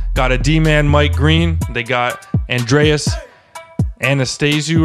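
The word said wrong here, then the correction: Athanasiou: I don't know how to pronounce his name, man. – Come on, bruv. It's your shit on point, man. It's Andreas Athanasiu.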